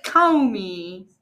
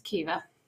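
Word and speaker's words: Caoimhe: No, it's Kiva. – It's Kiva.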